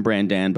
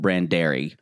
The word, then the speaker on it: Brandan: Mary and Brandan, we call him Brandan because of the way it's spelled. I think Maddie calls him Brandary, which to me sounds like an Irish tune.